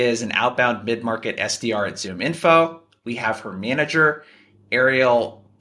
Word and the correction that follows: Arely: Bratton. I'm hoping I'm pronouncing that correct. – Airlie Bratton. – Airlie. I'm messing up locations. I'm messing up names today.